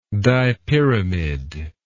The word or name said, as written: dipyramid